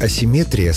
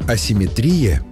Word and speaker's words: асимметрия: Вариант асимметрия словарями не поддерживается и считается ошибочным. Так что в русском языке симметрия есть, а вот асимметрии нет. Запомнить легко. Понятие «симметрия» — это когда все ровно и по центру. Даже ударение стоит по центру слова. А вот асимметрия — уже с нарушением баланса, поэтому и ударение съезжает к концу.